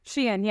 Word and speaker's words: Shein: And there's another one called Shein as well. – Shein, yeah. – Shein.